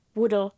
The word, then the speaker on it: Woodall: Hledala, jak se to vyslovuje, je to Woodall.